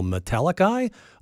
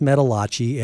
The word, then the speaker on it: Metalachi: So it's metal with A-C-H-I at the end. And I thought, well, Metallica? Okay, I'm going to assume there's something metal in this. No, it's pronounced Metallachi, and here Josh Gross is why.